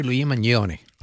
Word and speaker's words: Luigi Mangione: Se pronuncia así, Luigi Mangione.